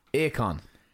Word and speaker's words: Akon: Akon.